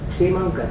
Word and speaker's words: क्षेमंकर: Number तीन क्षेमंकर, अब जो साधक है क्षेमंकर, मेरा क्षा का उच्चारण क्षेमंकर, अपन उसको आप लोग अपनी ऐसे क्षेमंकर बोलेंगे, मेरे से वो क्षेमंकर नहीं क्षेमंकर, काशा योग क्षा बनता है, क्षमा क्षेमंकर।